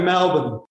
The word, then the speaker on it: Melbourne: Well, we say Melbourne. Melbourne. It's like, you know, yeah, we've got this way of speaking. It's like the g'day. Every time I hear the US say, Americans say g'day, it's kind of funny. But yeah, it's Melbourne.